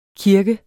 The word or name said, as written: kirke